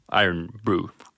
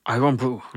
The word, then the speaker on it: Irn-Bru: Det stavas alltså I-R-N-B-R-U. Och så med skotskt uttal så ska det väl bli Iron Brew. – Iron Brew.